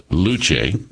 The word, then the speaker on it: Luce: The Luce, and it has here pronounced Luce, Will, because I was trying to figure out how to pronounce this, as a matter of fact, and here in the story it says the Luce, pronounced Luce.